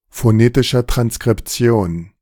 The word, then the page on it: phonetischer Transkription: German